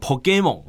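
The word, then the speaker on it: Pokémon: 日本如果用日文的发音叫做 Pokemon，好，那我们中文的翻译呢就叫做神奇宝贝，好，那这个宝可梦其实是它直接的音译啦，就是 Pokemon 的这个音译，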